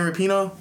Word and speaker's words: Rapinoe: You know, I'm like but then I said it at the same time, like, no, let me correct him this time. It's Megan Rapino.